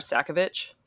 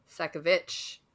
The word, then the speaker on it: Sakavic: In the audiobook, the narrator of the audiobook pronounces it Nora Sakovic. – Sakovic.